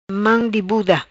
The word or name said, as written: Mam di buda